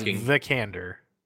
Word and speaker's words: Vikander: No, it is Vikander.